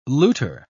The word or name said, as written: looter